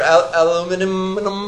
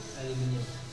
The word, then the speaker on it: aluminium: See, depending upon where you come from, you say it differently. And I'm from both places, so I get confused. Aluminium or aluminum?